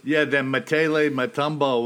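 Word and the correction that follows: Mokele-mbembe: whatever. – Makele Mbembe. – Oh, there you go. Now I got Makele Mbembe.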